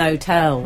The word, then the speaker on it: hotel: Like an hotel. – Oh, yeah. – No, but that doesn't begin with a vowel. – Well, it technically does, because H does. – Because the H is silent, meant to be.